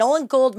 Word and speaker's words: Nolan Gould: Did I say his name right? – You said his name perfectly right. Yes. Nolan Gould, my fake son.